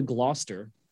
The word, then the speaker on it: Gloucester: I'm going to Gloucester.